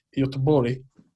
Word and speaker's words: Göteborg: These guys are from, Gothenburg, but I believe they pronounce it Göteborg.